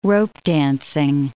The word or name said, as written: ropedancing